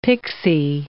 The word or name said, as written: pixie